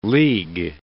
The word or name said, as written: league